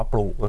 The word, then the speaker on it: PLOOK: ปลูกก็